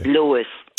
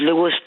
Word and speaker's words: Lewes: Is it Lewes, Delaware? Am I pronouncing that right? – Yes, it is. – Lewes, Delaware. What can I do for you? – Lewes. – Oh, Lewes, Delaware. I'm sorry.